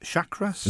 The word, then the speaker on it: chakras: Stage 4, how do you pronounce that? Chakras? – The chakras, yeah.